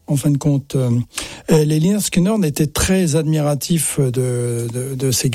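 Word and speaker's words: Lynyrd Skynyrd: Si vous aimez Lienerskinorn, Lienerskinorn, c'est comme ça qu'il faut le prononcer. Je fais toujours l'erreur.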